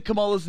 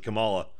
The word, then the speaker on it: Kamala: Wait, I said Kamala's name wrong. That's how much of a Trump person I am. – Yeah, she's going to get you. It is racist and sexist to pronounce it Kamala. It's Kamala.